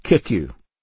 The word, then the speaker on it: CICCU: CICCU, pronounced KICU, an aggressively evangelical organization noted for its buttonholing tactics and lack of cooperation with other Christian groups.